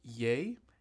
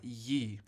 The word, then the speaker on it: Ye: He said, just to follow up on last week's episode, one of Kanye's album is pronounced Ye and not Ye.